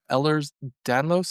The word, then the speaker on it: Ehlers-Danlos: So one of the ones that you were diagnosed is, I don't know if I'm pronouncing it right, Ehlers-Danlos syndrome, is that correct? – Yes.